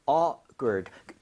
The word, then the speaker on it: awkward: 발음할 때 awkward. K가 약간 된 소리가 나요. 그래서 awkward가 아니라 awkward.